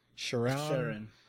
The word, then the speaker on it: Sheeran: One of my, if not my favorite moment, was his interaction with Ed How do you say his last name? Sheeran? – Sheeran. It's Sheeran.